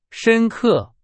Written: 深刻

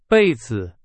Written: 被子

